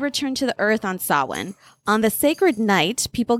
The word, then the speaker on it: Samhain: So it's written as spelled as like Samhain, Samhain. But when I Google the pronunciation, it's actually called Samhain.